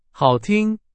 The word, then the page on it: 好听: Chinese